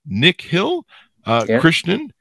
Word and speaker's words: Nikhil Krishnan: Nikhil, Krishnan is on the show with us today.